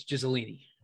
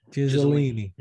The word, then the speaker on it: Ghislaine: And Epstein is now one of them, and now Ghislaine is going to be the next one. – Probably. – Ghislaine, I love it. – Ghislaine. – Yeah, I do the thing. It's Ghislaine. – Ghislaine.